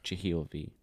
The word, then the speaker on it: Jahielv: Mine's just Jahielv.